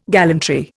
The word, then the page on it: gallantry: Indian